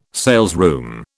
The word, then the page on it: salesroom: British English